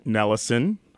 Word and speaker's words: Nelisson: All right, so Prairie 360, Devin Nellison.